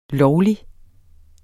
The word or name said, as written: lovlig